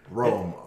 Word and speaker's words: Roem: Was it Rohm? Is that how you say it? – Rohm. R-O-H-M is Rohm? How they pronounce Rohm. It. Okay. But Rohm. – It's spelled like poem. Right. P-O-E is R-O-E-M.